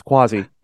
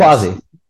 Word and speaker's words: Quasi: Well, that's Quasi, not Quasi. Wait, did I get that backwards? It's Quasi. – Yes. Quasi.